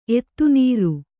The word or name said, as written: ಎತ್ತುನೀರು